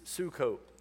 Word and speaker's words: Sukkot: Sukkot. That'd be the proper pronunciation.